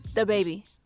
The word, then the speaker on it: DaBaby: DaBaby. – That was pretty good. – I mean, I don't... She put a lot less emphasis on duh.